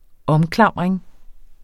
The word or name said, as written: OMKLAMRING